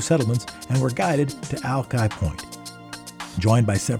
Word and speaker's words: Alki: Today, back in the day, November 13th, 1851, the Denny Party settled in Alki Point, Washington. Apparently, proper Chinook pronunciation is Alki, but no residents of the Washington area say it that way these days.